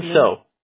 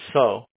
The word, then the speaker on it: Seo: Yeah, my real last name is spelled SEO, man. Yeah. – John So. Okay. – I swear, everyone doesn't believe it, but my last name is pronounced So, but I'm half Korean, that's why.